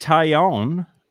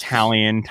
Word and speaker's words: Taillon: Yankees acquired Jameson Taillon. Is how I was told to say his name. Everyone says Talion, Taillon, Taillon. So we'll see what the officials say, but that's what we'll go for right now.